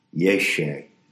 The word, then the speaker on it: yeshe: But whenever you receive it in telestic trance or otherwise, you're experiencing what the Tibetans call yeshe, spelled Y-E-S-H-E-S, but you don't pronounce the last S.